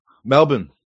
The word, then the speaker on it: Melbourne: Is it pronounced Melbourne or Melbourne? – Oh, we're Aussie, so I don't know. – Well, how do you guys say it? – Melbourne. – Melbourne. Okay. Melbourne.